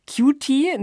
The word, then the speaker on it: QT: Also Cutie, ne, ist ja praktisch niedlich, süß, ausgesprochen das Q und das T der Seriennummer.